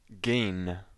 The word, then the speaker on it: Gein: Alex, the Mac voice is going to mess up his name. It's pronounced Gein.